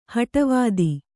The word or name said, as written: ಹಟವಾದಿ